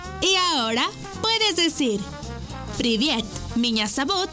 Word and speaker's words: Меня зовут: Y se escribe P-R-I-V-I-E-T. Привет. ¡Muy bien! Repite conmigo. Привет. ¡Muy bien! En ruso, me llamo se dice Меня зовут.